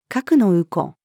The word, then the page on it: 格納庫: female